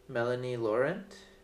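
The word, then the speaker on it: Mélanie Laurent: I don't know how to pronounce her name. – Melanie Laurent. Laurent. I think that's her.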